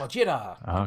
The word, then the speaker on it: Gojira: Is that how you say it, Gojira? Godzilla? – Mm-hmm. Gojira!